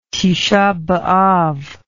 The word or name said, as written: Tishah b'Av